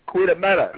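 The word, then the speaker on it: Goeie middag: Get a meta. – Fill in the blank, goddammit. – Get a knock. Quit. No, no, it's quit. It's quit a meta.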